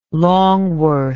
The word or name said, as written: Longworth